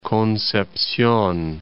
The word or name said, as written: Concepcion